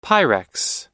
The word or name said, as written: pyrex